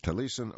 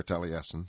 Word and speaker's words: Taliesin: And there's a couple of ways to pronounce that word I discovered. You can say Taliesin or Taliesin. And I kind of like the Essen, the way it reads here. It's spelled T-A-L-I-E-S-I-N.